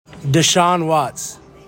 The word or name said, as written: DESEAN WATTS